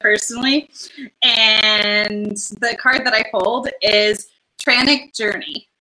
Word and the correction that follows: Tantric: I'm sorry. Tantric Journey. I mispronounced that.